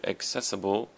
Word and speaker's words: accessible: So access has the emphasis on the a, access, and then for the word accessible, the emphasis is on the ses, accessible.